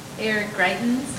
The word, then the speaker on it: Eric Greitens: Eric Greitens.